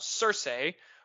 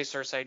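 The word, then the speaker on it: Sersi: I'm going to say Cersei just because it's spelled S-E-R-S-E-I. So it's got that eh on the end of it.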